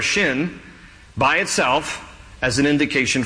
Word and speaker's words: Shin: If there's a dot over the left branch, it is pronounced seam. If it is over the right branch, it is pronounced shin.